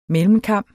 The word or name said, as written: mellemkam